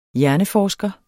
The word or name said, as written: hjerneforsker